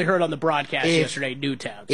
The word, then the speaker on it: Newtown: Newton Square, Pennsylvania. I feel like Newtown. – Oh, is it really? It's Newtown? – Yeah.